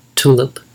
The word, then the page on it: tulip: American English